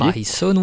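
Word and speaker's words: Mein Sohn: Ah bah oui, c'est de l'allemand, hein. Mind Zone, ça veut dire mon fils. Et c'est écrit en allemand, donc c'est pour ça que je le prononce comme ça.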